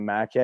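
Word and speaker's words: Mackay: So I think them spelling my name wrong on my jersey and it being Mickey instead of Mackey, I think that's how that all started.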